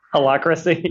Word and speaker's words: Holacracy: How do you pronounce it? Heliocracy? – Holacracy. – There you go.